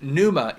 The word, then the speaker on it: pneuma: Because I want you to know the Greek word is actually pneuma. It's pronounced pneuma, and the meaning is breath or wind.